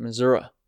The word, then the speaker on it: Missouri: Or if you ask people in Missouri who are really from Missouri, they just might say Missouri River. Because that's how they would pronounce it, Missouri.